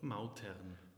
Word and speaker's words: Mautern: Mautern.